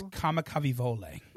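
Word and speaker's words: Kamikavivole (Kamakawiwoʻole): It's not that it's that hard to pronounce once you know it. – Right. – But, like, the reading of it is so it's so not in my brain of that's just that's how that's pronounced. – Yeah, I knew. – But yes, it's Kamikavivole. – Right. I knew the W's in Hawaiian had more of a V sound, but I still was going to struggle with that.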